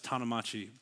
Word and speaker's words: Tanamachi: My last name is pronounced Tanamachi, by the way, if you wondered. It is Japanese, which we will also talk about later in the sermon.